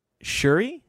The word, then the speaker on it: Shuri: I'm very eager to see how they, including Shuri, is that how you pronounce her name? – Mm-hmm.